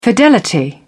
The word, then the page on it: fidelity: British English